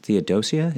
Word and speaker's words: Theodosia: But I think for me, rumors of his daughter, and I think her name is pronounced Theodosia, is that right, approximately? – Yes.